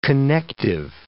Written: connective

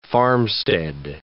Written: farmstead

